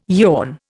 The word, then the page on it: yawn: Indian